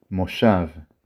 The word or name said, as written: מושב